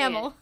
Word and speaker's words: Hamill: We have our stars, which are Mark Hamlin, Hamill? How do you say Hamill. – It? Hamill. There we go.